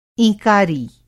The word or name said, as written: ईंकारी